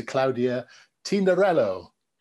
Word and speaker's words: Claudia Tinarello: So welcome to Claudia Tinarello. – Hello, hi. Thank you very much. That was a good pronunciation, by the way. – Not bad. Claudia hey? Eh? – Yes, you sounded nearly Italian.